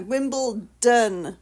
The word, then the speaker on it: Wimbledon: Americans say Wimbledon, and it literally drives me to... – It's a don. – What? – D-O-N. It's a don on the end. – Yeah, Wimbledon, Don, not Ton, Tom.